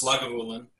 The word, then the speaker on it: Lagavulin: So it is pronounced Lagavulin.